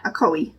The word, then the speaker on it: Ocoee: Akoee. O-C-O-E-E. – Yeah. – Akoee. – Yep.